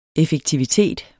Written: effektivitet

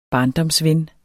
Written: barndomsven

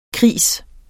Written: kris

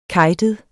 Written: kejtet

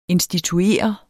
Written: instituere

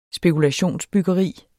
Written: spekulation

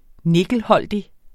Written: nikkelholdig

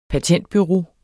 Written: patentbureau

